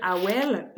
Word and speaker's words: Aweil: dans une ville au début, quand j'ai eu le contrat, ça s'appelle Awel, la ville, mais on dirait que ça s'écrit comme en, way, genre, Ah ouais, ouais, c'est ça.